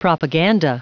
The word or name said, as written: propaganda